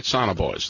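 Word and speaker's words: sonobuoy: You, first of all, drop the Sonoboys. – Mm-hmm. – Am I saying that right? Sonoboys. There we go.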